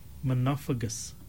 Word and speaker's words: monophagous: The word that we were looking for today, Marana, is the word monophagus.